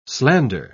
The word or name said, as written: slander